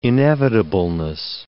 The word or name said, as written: inevitableness